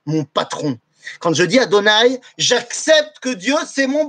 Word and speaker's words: Adonai: Et quand je dis Adonai, je ne dis pas Yudke Vavke que je prononce comme étant Adonai.